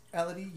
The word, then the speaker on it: Elodie: Elodie Young. – I don't know why I didn't correct you before, but it's Elodie. – Good to know. – Like Melody.